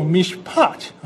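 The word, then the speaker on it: mishpat (משפט): 而以赛亚呢，他在二章四节所用的审判是名词，是在动词的字根上加上一个前缀词，我们把它念作 mishpat，然后就改成 p 了哈。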